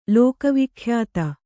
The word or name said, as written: ಲೋಕವಿಖ್ಯಾತ